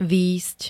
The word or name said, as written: vyjsť